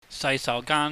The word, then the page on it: saisaugaan: Cantonese